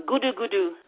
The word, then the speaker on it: Gudu Gudu: The Tales of Gudu Gudu, which is how the quake, the name for the quake. It's an onomatopoeia of the sound that the ground made when it shook.